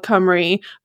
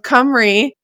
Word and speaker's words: Cymru: and the country is also called Cymru, but one is spelled, so the people is spelled C-Y-M-R-Y, and then the country is spelled C-Y-M-R-U, but they are pronounced the same.